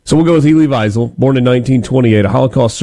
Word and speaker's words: Wiesel: I think it's Wiesel. I Wiesel? – Yeah. – And it's like we just flipped the W and the V? – I believe so.